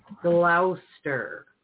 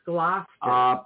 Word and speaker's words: Gloucester: We have a place that is spelled, and people say it like this, Gloucester. – Yeah. – But it's Gloucester.